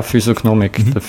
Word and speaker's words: Physiognomik: Und was ist, du hast auch aufgeführt, Physiognomik, habe ich es richtig gesagt? – Ja, Physiognomik.